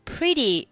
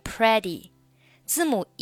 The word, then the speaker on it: pretty: Pretty 这个单词要注意啊，不要读成 pretty，字母 e 发的是短元音 i i pretty pretty decent.